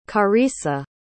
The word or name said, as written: charissa